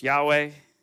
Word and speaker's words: Yahweh: And then there's his personal name, which Most scholars believe it's pronounced Yahweh. And the reason that I say that is because in Hebrew, there's no vowels in the Hebrew alphabet. They were later additions, but it's just YHWH.